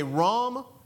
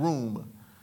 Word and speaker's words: rum: And it looks like you should say rum, but we're trying to be sober. But the proper way to say it is, if you want to write it down, room. That is the alliteration, or the best way to say it, R-U-M.